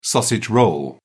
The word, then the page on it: sausage roll: British English